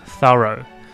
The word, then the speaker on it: thorough: THO の部分にアクセントがあります。最初の THO の thor。で、後ろの row。後ろ弱く row です。 thorough。